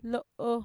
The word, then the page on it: lo'o: Mixtec